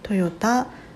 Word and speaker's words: 豊田: ましたで自分が住んでいる地域はトヨタの工場津に囲まれていてうん本当なんて言うんだろう市内に工場がたくさんあるですけどうん田んぼも多かったりするので豊かな田んぼと書いてトヨタと読むような場所に住んでおります。